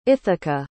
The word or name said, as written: ithaca